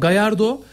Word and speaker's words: Gallardo: Gallardo diye mi okunuyor? – Evet abi. – Tamam, Gallardo ve yine onun gibi şu anda boşta olan İtalyan Antonio Conte de Fenerbahçe'de Ali Koç'un ilk temasları sağladığı kişi.